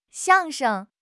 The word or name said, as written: 相声